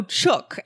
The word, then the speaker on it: chook: So chook. Now, it's pronounced the same as book.